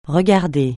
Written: regarder

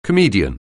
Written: comedian

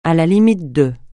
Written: à la limite de

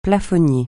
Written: plafonnier

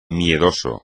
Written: miedoso

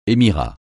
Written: émirat

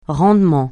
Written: rendement

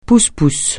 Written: pousse-pousse

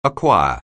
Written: acquire

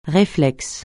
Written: réflexe

